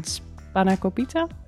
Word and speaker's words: spanakopita: Op woensdag een spanakopita.